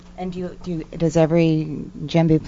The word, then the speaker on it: djembe: Djembe.